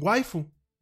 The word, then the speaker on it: waifu: Entonces, en vez de decir wife, ellos dicen waifu, waifu, así como tal, y lo escriben waifu.